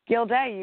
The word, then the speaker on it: Guilday: What is the proper pronunciation of your last name? – Guilday. You guys nailed it. Both nailed it.